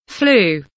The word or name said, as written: flu